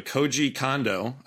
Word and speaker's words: Koji Kondo: He was the original composer for the audio, Koji Kondo, I believe.